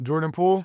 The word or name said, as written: jordan POOLE